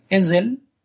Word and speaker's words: Ezel: Y bueno, los Bañar para el verde usaban Ezel, escrito con Z pero pronunciado con S sonora.